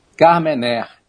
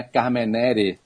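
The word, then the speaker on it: Carmenère: Carmener, não é Carmenere. Não é errado, mas assim, pronunciar o original francês correto seria Carmener.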